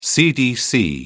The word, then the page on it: cdc: British English